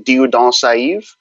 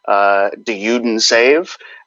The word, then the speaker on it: Dieudonné Saïve: Probably the more correct way would be Dieudonné Saïve. But I've also heard it, I guess you can call it Americanized, of just Dieudonné Saïve.